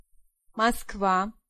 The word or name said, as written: Moscow